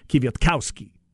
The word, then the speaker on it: Kwiatkowski: Next to last syllable is where they put the emphasis. Kwiatkowski. Or if you want to go Kwiatkowski, you can do that too. That's how I believe it is pronounced.